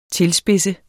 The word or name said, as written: tilspidse